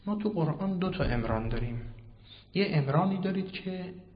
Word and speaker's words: عمران: اگه بخواد عربی بشه با چطور تلفظش کنیم؟ باید عمران بگیم، مهندسی عمران میگید، بالاخره این میشه عمران بر وزن فعلان میشه مصدر عربی، یعنی آبادی، مثل فرقان این میشه مصدر من، اما این فعلانه، این امرانه و این عربی نیست.